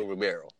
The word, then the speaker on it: Ribeiro: you know, I know it's Alfonso Ribeiro. Ribeiro. The I or the E is silent, uh, however you decide to pronounce it.